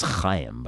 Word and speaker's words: Haim: And in smaller but sad news, actor Corey Haim died, whose name really should have been pronounced Chaim, by the way, possibly of drug complications, at the age of 38 last week.